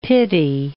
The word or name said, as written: pity